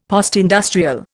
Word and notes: postindustrial: Indian

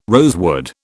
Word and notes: rosewood: British English

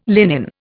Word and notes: linen: Indian

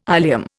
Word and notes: ilium: Indian

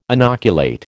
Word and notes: inoculate: American English

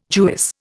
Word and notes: Jewess: Indian

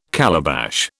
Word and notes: calabash: British English

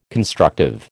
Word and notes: constructive: American English

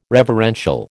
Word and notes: reverential: American English

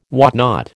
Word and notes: whatnot: American English

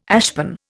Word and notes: ashpan: Indian

